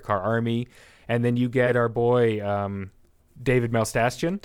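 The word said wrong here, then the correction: Dastmalchian: Dasmalchian. – David Dasmalchian as Peter DeVries.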